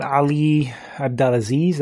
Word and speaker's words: Abdelaziz: And Izzy was pretty much getting on Ali Abdelaziz, that's how you pronounce his name.